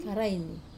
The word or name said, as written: karaini